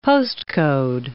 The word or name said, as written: postcode